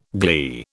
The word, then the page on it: glee: British English